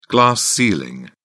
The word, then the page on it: glass ceiling: British English